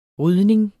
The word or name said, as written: rydning